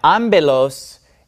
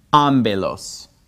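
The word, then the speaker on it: ambelos (ἄμπελος): Usually, this letter makes the P sound, but whenever P comes after me, it actually makes a B sound. That's just an exception to the rule. So every time you see a P following a me, you always pronounce it like a B. Therefore, this word is pronounced ambelos. Ambelos.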